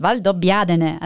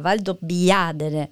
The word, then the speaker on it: Valdobbiadene: Also übrigens, ich bin ja die Aussprachepolizei, sagt man mir immer, weil alle immer Valdobbiadene sagen. Ja. Ich darf vielleicht nochmal unterstreichen, es heißt Valdobbiadene, also die Betonung liegt auf dem A, Valdobbiadene und dann so Dene nur so hinten raus, genau.